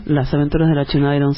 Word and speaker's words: Las aventuras de la China Iron: Estamos en Nosotros y los Medios, estamos con Gabriela Cabezón Cámara, que era quien nos contaba recién un ratito de las aventuras de la China Iron, ¿así es? ¿Lo dije bien? – Las aventuras de la China Iron, sí señor.